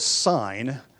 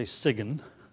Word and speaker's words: sign: But when we use the word sign as a noun, we don't pronounce the G. You don't say siggen, right?